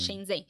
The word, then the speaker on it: Shenzhen: Shenzhen. Nem sei se eu, se eu tô falando certo. Sim. – Shenzhen.